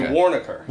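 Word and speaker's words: Waronker: Isn't Joey Waronka? How do I say that name? – Joey Warnaker. – Warnaker.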